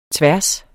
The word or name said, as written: tværs